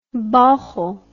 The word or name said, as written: bauchle